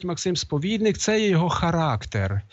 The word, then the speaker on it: характер: Але тут йдеться про щось інше, характер. Ось наголос на останньому, це грецьке слово.